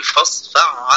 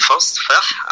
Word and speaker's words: فاصفح: هي كده تهيأت للإدغام، يعني سهل إن أنت تقول فاصفع عنهم. تمام؟ يبقى هنا بقى إيه الاهتمام بيها؟ أولى وآكد تقول فاصفح عنهم، فاصفح عنهم.